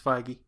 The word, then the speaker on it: Feige: It's Feige.